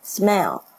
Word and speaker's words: smell: 微笑跟味道，一个是 i 的声音，是 a 的声音，smell smell。闻起来的味道不是 smile，不是微笑，所以呢，这两这两个发音稍微区别一下就会更好。